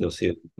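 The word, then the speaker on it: Gnossienne: But from what little I know of French, I think it's pronounced Nocian number one.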